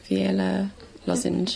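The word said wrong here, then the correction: Veli Lošinj: Veli Lozinj.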